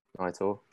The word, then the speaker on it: NITOR: So that's then, so for your business at the moment, NITOR, am I saying that right? NITOR training? – Yeah. NITOR. What is it? Everyone says NITOR, but I'm like, yeah. NITOR training.